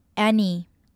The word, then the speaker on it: any: 首先我们看到 doubt 要注意的是字尾的 B 其实不发音 doubt 再来看到 about it 在这里我们会做连音，因为 about 的字尾是一个子音，然后 it 是一个母音开头 about it 再来看到 any 我们要注意到它的 A 发的是 at 的音 any